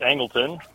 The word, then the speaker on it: Angleton: It's pronounced Angleton. – That's what I was going to say.